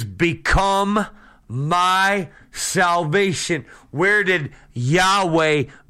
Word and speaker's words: Yahweh: Now, Previously, there was no J. That is a way of pronouncing what we call the Tetragrammaton. Yahweh. YHWH. Yahweh.